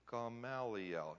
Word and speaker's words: Gamaliel: The uber rabbi, Gamaliel, or however you say his name, stands up. Gamaliel, yeah.